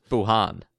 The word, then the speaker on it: Wuhan: It was Wuhan.